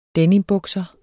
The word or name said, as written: denimbukser